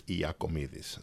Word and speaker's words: Iakomidis: And in Greek, it's Iakomidis. So that's the more correct way to say it.